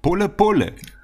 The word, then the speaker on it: pole pole: Äh, und das heißt auf Suahelisch pole, pole.